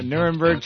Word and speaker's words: Nuremberg: Yeah, Nuremberg trials.